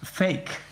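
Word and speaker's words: PHEIC: which is pronounced the acronym is pronounced fake.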